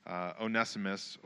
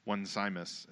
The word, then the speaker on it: Onesimus: So if you're reading the book of Philemon, now you know to pronounce the name Onesimus and not Onesimus, just as a clarification for everybody.